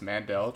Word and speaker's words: Mandel: We watched a video on that pronunciation about 5 minutes ago, and we can confirm it's Mandel, just like sugar.